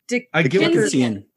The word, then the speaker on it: Dickensian: How do you say that? – Dickensian. Dickensian. – Dickens. Well, it's got to be Dickens. – Ian. – That's the name. Dickensian. Dickensian. Yeah. There you go.